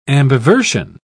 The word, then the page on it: ambiversion: British English